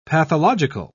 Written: pathological

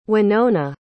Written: winona

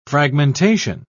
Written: fragmentation

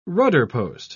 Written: rudderpost

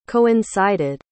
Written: coincided

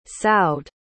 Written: saud

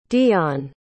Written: deion

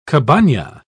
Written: cabana

